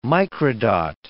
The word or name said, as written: microdot